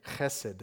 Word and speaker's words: chesed: The Hebrew word, it's fun to say, chesed. You got to get that little raspy sound at the beginning there.